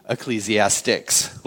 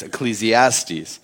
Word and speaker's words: Ecclesiastes: Because I'm teaching out of Ecclesiastes, which for those of you who don't know, it's not pronounced Ecclesiastics, like I said for years. It's Ecclesiastes.